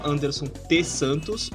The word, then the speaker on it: Anderson T Santos: No Instagram eu tô com arroba Anderson T Santos, o T é mudo, e no Twitter é underline Anderson TS.